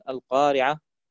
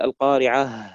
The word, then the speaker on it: القارعة: يعني أحيانا يقف على بعض القراء يقفون على كلمة نهايتها حرف الهاء فلا يظهر هذا الصوت بشكل واضح، يعني يقول مثلا الحاقة، أين حرف الهاء؟ ذهب. مثلا القارعة، أين حرف الهاء؟ لا يوجد. يجب أن يقول القارعة، بهذه الطريقة يكون نطقه صحيح.